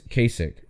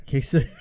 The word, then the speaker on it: Kasich: It's Kasich. Rhymes with basic. – Okay. Kasich.